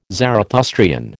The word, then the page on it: Zarathustrian: American English